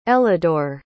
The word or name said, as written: elidor